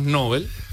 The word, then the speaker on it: Nobel: El sustantivo Nobel y el adjetivo Nobel, según nuestro oyente, son voces agudas. Se tildan en la última sílaba.